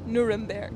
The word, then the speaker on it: Nuremberg: Nuremberg. – Yes. – There you go. Did I say that correctly? – Nuremberg. – Nuremberg, thank you.